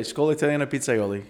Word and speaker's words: Scuola Italiana Pizzaioli: He's the founder of the North American Pizza and Culinary Academy here in Chicago, I guess, the second school, the Scuola Italiana Pizzaioli. I pronounced that right. – Yeah, Scuola Italiana Pizzaioli.